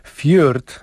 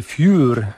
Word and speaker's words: Fjord: Fjord, a właściwie Fjur, jak mówił mi Martin Weinert. Podobno tak Norwegowie wymawiają to słowo.